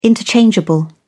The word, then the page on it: interchangeable: British English